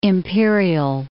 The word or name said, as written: Imperial Beach